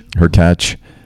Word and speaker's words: Hurkacz: Hurkacz.